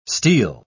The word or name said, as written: steel